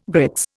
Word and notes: grits: Indian

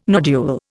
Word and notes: nodule: Indian

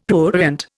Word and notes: prurient: Indian

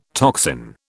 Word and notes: toxin: British English

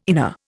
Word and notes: inner: Indian

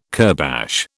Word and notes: kurbash: British English

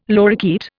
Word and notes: lorikeet: Indian

